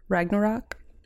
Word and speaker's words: Ragnarok: My actor was in Thor Ragnarok. You like how I said that? – Yeah. I think you said it right.